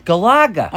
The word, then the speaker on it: Galaga: Galaga.